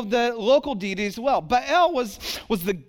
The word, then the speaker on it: Baal: I have a whole sheet of study on Baal, and that's how you say it, Baal.